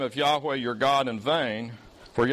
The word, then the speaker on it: Yahweh: Yahweh is four Hebrew consonants, and we're pretty sure that those four consonants were pronounced Yahweh.